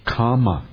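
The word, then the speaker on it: kāma: Kama is the word. It's not the same as kama. It's two different words. Kama is one word. Kama is another one. And kama is spelled with double M, same as karma in Sanskrit. But kama is just only has a single M, but a long A in it.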